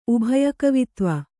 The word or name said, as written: ಉಭಯಕವಿತ್ವ